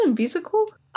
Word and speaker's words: imbecile: you is it imbecile? I-M-B-E-C-I-L-E, the insult. – Imbecile. – I can't pronounce that word. Imbecile. – Imbecile.